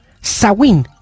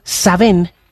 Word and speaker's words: Samhain: Dice Samaín, pronunciado Sawin. Es la palabra del gaélico irlandés que designa el mes de noviembre. Mientras que en gaélico escocés, Samhain, pronunciado Saben, designa la fiesta de All Hallows.